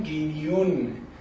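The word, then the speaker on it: Guignon: دوباره که بعد از چاپ کتاب نگاه کردم دیدم گینیون، یعنی این یه جوری تلفظ میادم، هم گش میشنوی هم نه، ولی گینیون،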